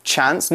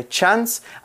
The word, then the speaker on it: chance: That's right, there are two pronunciations of this word. So we can say chance. Normally in the US and some parts of the UK, they will say chance.